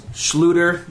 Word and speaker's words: Schluter: And is there a schooling, or like the guys that do this, does Schluter, that's how you say it, right? – That's correct.